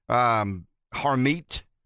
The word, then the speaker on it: Harmeet: I mean, it's D-H-I-L-L-O-N. I mean, she works for the law firm Harmeet.